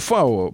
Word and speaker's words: Фау: Вот, это читается по-немецки фау. Оно вообще звук F, то есть вот фатер слово, оно пишется через V, как бы, да.